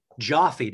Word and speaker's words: Joffe: Talking with Susannah Joffe. Joffe, did I say that correctly? – Yes, good job. You did. Most people don't get that right.